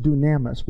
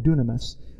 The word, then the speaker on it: dunamis: One is pronounced dunamis. We call it dunamis. Dunamis.